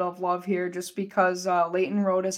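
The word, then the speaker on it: Lleyton Roed: And then your player to watch is, you know, someone who, with just an absurd name, just spelling wise, but it's Leighton Rhode, spelled L-L-E-Y-T-O-N and then R-O-E-D. I mean, just spell it phonetically, please, but, you know, to each their own, of Bemidji State.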